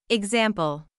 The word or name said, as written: example